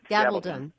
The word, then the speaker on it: Gabaldon: Gabaldon.